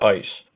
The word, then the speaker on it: ISE: or ICE, which is pronounced like ice as in ice cube, so it's ISE.